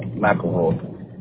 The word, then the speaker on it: McElroy: No, McElroy.